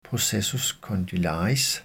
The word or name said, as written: processus condylaris